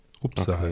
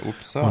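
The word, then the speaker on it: Upsahl: Ich habe, ähm, ich bin jetzt nicht sicher, ob ich den Namen von der Band richtig ausspreche. Ähm, und zwar Upsal oder Apsel oder so, keine Ahnung. Upsal geschrieben. E U-P-S-A-H-L. – Upsal. Okay, Upsal.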